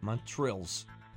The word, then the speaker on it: Montrezl: I don't know how to pronounce that first name. – Yeah. – It's Montrezl. – Okay. – Montrezl. There you go.